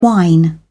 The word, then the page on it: whine: British English